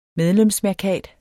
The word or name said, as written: medlemsmærkat